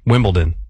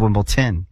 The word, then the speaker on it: Wimbledon: Yeah, I got D-O-N. – Wimbledon? – It's D-O-N. – Like downtown. – Okay, hold on. Say it correctly again. – Wimbledon.